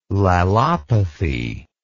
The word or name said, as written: lalopathy